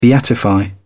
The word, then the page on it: beatify: British English